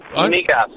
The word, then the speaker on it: migas: Migas. – Migas. Yeah, I got Matt Wilkinson screaming at me from outside the studio going, "You idiot." Amigas. Okay. – Yeah. Also, a dish that in Portugal pronounced migas.